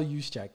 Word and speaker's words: Juszczyk: Kyle Juszczyk, shout out. Shout out fullbacks. Make fullbacks great again, please. – Yes, and thank you for helping me on my pronunciation of his last name. – Kyle Juszczyk, yeah.